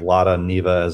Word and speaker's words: Lada Niva: Yeah, a lot of people in the comments mentioned Lada Niva as another.